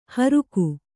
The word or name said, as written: ಹೋರ್ಕೆ